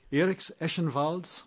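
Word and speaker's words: Ēriks Ešenvalds: Eriks Eschenvalds.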